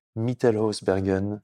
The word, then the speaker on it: Mittelhausbergen: Merci Pierre-Hugues Herbert de nous avoir reçus chez toi à Mittelhausbergen. – C'est bien, tu le prononces bien. Bien, c'est hein. Pas mal. Ouais. En allemand, ça fait Mittelhausbergen.